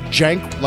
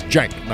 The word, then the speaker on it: Cenk: I've always said Cenk, like with a J, and that's exactly. Yeah. Yes. I've been right all along. – That's correct. Yes. – Cenk, not Cenk or Sank or whatever.